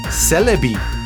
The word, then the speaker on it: Celebi: retro games en soms zelfs Celebi. Zeg ik dat goed, jongens? Dat heb je goed gezegd. Dat zeg je goed.